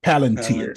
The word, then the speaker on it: Palantir: Let's say I pick Planter Technologies because I know nothing about it. Okay. I know nothing. That's not even Planchet. I didn't even say the name right. Palantir. – Palantir.